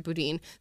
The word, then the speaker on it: Boudin: Like we just saw in San Francisco, Boudin, or however you pronounce his last name, I think you pronounce it Boudin, the DA there, was just recalled.